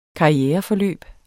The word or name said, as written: karriereforløb